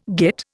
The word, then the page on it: git: Indian